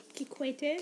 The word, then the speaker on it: Kikwete: how do you pronounce that word? – Kikwete. – Mm-hmm. – That's our president.